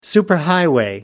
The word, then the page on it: superhighway: American English